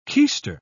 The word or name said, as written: keister